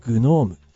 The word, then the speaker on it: GNoME: This process powered by DeepMind's new deep learning tool, GNOME. GNOME は地上の地、地の妖精、地の精霊という意味なんですね。まあ Google ですから、あえて頭の G、これを発音するようですね。 GNOME。